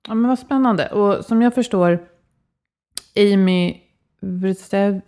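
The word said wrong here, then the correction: Wrzesniewski: nej, men det var jättesvårt att uttala. – Wisniewski, låter bra.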